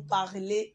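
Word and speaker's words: parlent: So, we have something like P-A-R-L-O-N-S for nous parlons. Then P-A-R-L-E-Z for parler. EZ. And then for masculine plural, il, we have parle, which is spelled P-A-R-L-E-N-T. E-N-T. So E-N-T is the only thing that is added. E-N-T. So with that E-N-T, it marks the plurality in the sentence.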